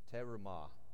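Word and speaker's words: terumah (תרומה): The word contribution in Hebrew is terumah, and I don't do the pronunciation right. It's definitely a Georgia pronunciation, but it's terumah, and it's the heave offering.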